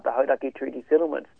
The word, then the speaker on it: Hauraki: And for me, you know, start maybe, as we say, it's Hauraki District Council, not Haraki, as an yes.